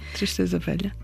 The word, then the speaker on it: Tristeza Velha: Là, on vient d'écouter le titre Tristeza Velha, comme ça qu'on prononce. – Oui, très bien. Tristeza Velha.